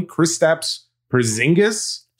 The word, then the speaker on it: Kristaps Porzingis: Kristaps Porzingis.